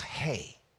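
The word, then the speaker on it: hey (ἡ): It's not pronounced like an N. It's an A sounding, and it has that little apostrophe going to the other direction, so it's pronounced hey. Say that with me, hey.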